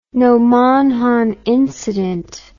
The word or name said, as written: nomonhan incident